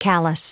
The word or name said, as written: callous